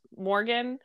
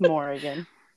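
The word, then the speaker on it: Morrigan: You know, but I did see the one where she, her inner court, Morgan. That's not how I said that in my head ever. – Morgan? – Yeah, no. There's an extra I. There's an extra syllable in there.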